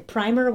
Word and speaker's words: primer: Primer with the long I, like we're saying it. That's what they're talking about. Substance using as a preparatory coat on previously unpainted wood, etc. A small pump for pumping fuel. And a couple other ones. And then primer, without the long I, and they talk about an elementary textbook that serves as an introduction to a subject. So in this case, according to Google, it's two different words pronounced differently.